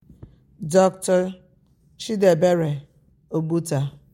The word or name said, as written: Dr. Chidiebere Ogbuta